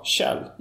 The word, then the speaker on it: Kjell: Logotypen är ett snäckskal, och på engelska så heter, uttalar man snäckskal Kjell, inte Själv.